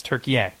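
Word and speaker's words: Türkiye: Well, hold on A-Y-E? – A sec. Hold on a sec. It's yeah, that it, it that is how it's spelled. But they now say the pronunciation is Turkey.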